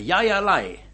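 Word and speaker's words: jai alai: The Basques, you know, B-A-S-Q-U-E. S-K. The Yaya Lai. All right, I'll spell that. Y-A-Y-A-L-A-I. Pronounced Yaya Lai.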